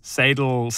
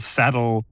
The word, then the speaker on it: Sadil: And I've heard people pronounce Sedil different ways. Sadel, saddle, however you want to say it, it's a five-letter surname. S-A-D-I-L.